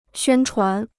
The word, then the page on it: 宣传: xuān chuán